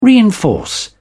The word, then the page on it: reinforce: British English